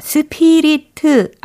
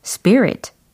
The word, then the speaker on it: spirit: 아, 스피리트 아니고, 스프리트 아니고, 스 p 릿 이거겠죠? 철자는요? S-P-I-R-I-T.